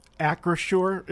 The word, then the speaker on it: Acrisure: Acrisure is what you said it right. Yeah. I get, yeah, Acrisure.